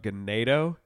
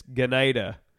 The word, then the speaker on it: Ganado: And then we also played this one town called Ganado. – I haven't heard of that one. – It's, I'm saying it wrong. They corrected us when we were there. It's Ganada.